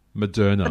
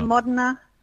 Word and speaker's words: Moderna: Moderna. – The Moderna? – Moderna. – Moderna. Moderna. – Oh, thank you. Thank you for helping with that. Moderna becomes available this week,